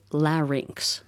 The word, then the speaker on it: larynx: And it's called a larynx. It rhymes with the word sphinx, if you want to think of it that way.